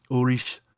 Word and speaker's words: Ulrich: And I love Ulrich, so Ulrich, as he pronounces it, and so I thought that would be a good pick.